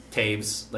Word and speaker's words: Toews: And my last name is nope, Taves, like T-O, but it's spelled T-O-E-W-S. – T-O-E-W-S.